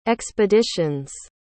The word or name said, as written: expeditions